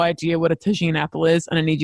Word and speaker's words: tagine: It's a tagine. – Oh, tagine.